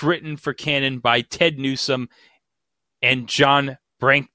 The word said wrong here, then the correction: Brancato: I hope I pronounce this right. John Brancato? John Brank? – John Brancato, yes.